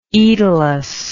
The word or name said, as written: aetolus